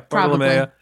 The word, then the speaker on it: Bartolomea: And you could almost say, like, oh, well, they're trying to use her relationship with Bartolomea. Am I have that right? Am I saying that right? Yeah, Bartolomea.